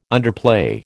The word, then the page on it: underplay: American English